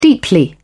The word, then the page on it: deeply: British English